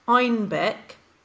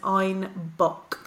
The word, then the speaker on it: Einbeck: By the 1600s, it was also being brewed in southern Germany, and the Bavarian accent pronounced Einbeck as Einbock.